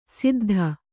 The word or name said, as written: ಸಿದ್ಧ